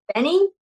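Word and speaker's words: BENEE: Also, it's Benny, not Benet.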